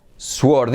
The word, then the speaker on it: sword: Muy bien, vamos a ver la número 4, espada, escrito sword. ¿Y cómo se dice espada? Venga, os dejo tiempo. Sword, sword, no, sword, sword.